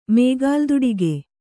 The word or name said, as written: ಮೇಗಾಲ್ದುಡಿಗೆ